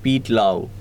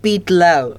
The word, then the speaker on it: Pete Lau: It says P-E-T-A. How's P-E-T-A pronounced? – That, you know, I heard it in the launch, even I had this pronunciation issue. So it was like Pete Lau. – Okay, fine. We'll go with Pete Lau.